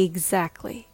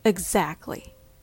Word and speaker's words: exactly: It's not e exactly. It's uh, uh, exactly.